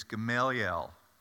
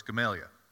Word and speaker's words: Gamaliel: His correct way to say it is Gamaliel. Now, I grew up in the South, so it's Gamaliel.